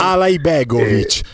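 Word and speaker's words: Alajbegović: Alaibegovic, ma tu fai Ala J, così Ala con la J della Juve, siamo a posto.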